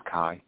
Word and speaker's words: Khai: Yeah, my name is Kai. – How do you spell that? – K-H-A-I. – K-H-A-I.